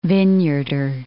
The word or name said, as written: Vineyarder